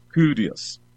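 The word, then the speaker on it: Kyrios (κύριος): Curios, Curios, o según la pronunciación del griego moderno, Kyrios. Pero en la pronunciación de la época Koiné, Kyrios.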